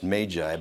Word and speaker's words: Magi: The Magi, and they say it's pronounced Magi, but nobody does, are a caste of wise men specializing in astrology, medicine, and natural science.